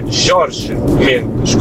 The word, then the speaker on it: Jorge Mendes: Il buon Mendes, procuratore, che è Mendes, non si pronuncia Jorge alla spagnola, dai. – È vero. – Visto che ci si vanta delle pronunce portoghesi, è Jorge Mendes, così come mi tocca correggere anche il buon direttorissimo Loreto, con Nuno Gomes, non si può più sentire, è Nuno Gomes.